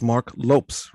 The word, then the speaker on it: Marc Lopes: It's Marc Lopes, right? – Yes.